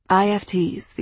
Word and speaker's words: IFTs: IFTs, these futures were called, pronounced like the word if, inadmissible future trajectories.